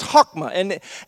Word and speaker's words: chokmah: it's pronounced in Hebrew chokmah. I know it kind of looks like chokmah, but it's pronounced chokmah, and what it essentially means, it's first found in our focus verse in Exodus 28:3, where we just read.